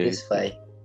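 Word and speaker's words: Tesfaye: Tesfai, como é que se pronuncia? – É, Tesfai. – Ah, Tesfai.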